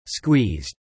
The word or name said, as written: squeezed